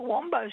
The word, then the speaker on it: Wambo: Other one, we have a Wombo Street, which is spelt Wambo Street.